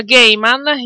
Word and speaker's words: Gaiman: Comunque, Veleneo mi dice che si pronuncia Gaiman. Io ho sempre detto Gaiman.